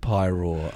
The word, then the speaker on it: Paeroa: They went to the planet Pyrore, I think. – Did they? How was that? It's spelled P-A-E-R-O-A, and it's a town called Pyrore.